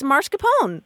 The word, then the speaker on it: mascarpone: And she said, oh, it's easy. It's mascarpone.